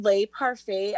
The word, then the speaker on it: Le Parfait: It's just called Le Parfait.